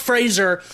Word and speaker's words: Fraser: It's pronounced Fraser. Everyone always gets it wrong.